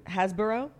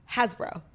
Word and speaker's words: Hasbro: Hasbro. You're saying it wrong again. – Okay. – Hasbro. – Okay. – It's H-A-S-B-R-O. Like, you're adding like an O-U-G-H. Hasbro.